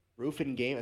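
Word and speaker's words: Ruffian Games: Rufin Game, I think that's how you say it. Ruffian. Rufin. Rufin Games purchased by Rockstar.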